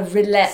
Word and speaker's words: rillette: R-I-L-L-E-T-T-E. – A roulette. – So it's a roulette. – French. – Oh, it origin. Sounds like, but what's this on your shoulders? – That's epaulette. An epaulette. – That's an epaulette. – Oh, yes. – So, roulette.